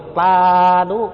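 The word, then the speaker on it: sādhu: Chữ ớt này họ đọc là chữ t, cho nên sa đú á, đấy mình đọc là sa đú đúng không, mà người Miến đọc là ta đú chứ không phải là sa đú nha.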